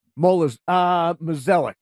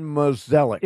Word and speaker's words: Mozeliak: If they keep, how do you pronounce, is it Molizak? – Mozeliak. – Mozeliak? – John Mozeliak.